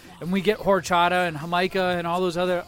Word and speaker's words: jamaica: It's spelled like Jamaica, though. – You had it right.